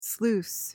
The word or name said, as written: sluice